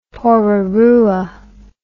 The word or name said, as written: Porirua